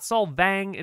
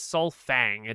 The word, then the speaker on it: Solfang: it is not Solvang, it is Solfang. It is like Sunfang.